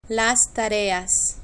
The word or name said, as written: las tareas